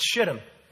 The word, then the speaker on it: Shittim: You know, I did a study, and I wanted to know how the Hebrew word was supposed to be pronounced. And I really did quite a study on this. And you know what I discovered? It's Shittim.